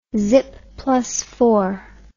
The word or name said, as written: zippidy do dah